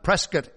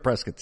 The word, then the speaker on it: Prescott: Prescott? Well, why don't you spell it like biscuit?" My favorite Prescott story.